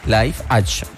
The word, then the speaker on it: live action: Live action.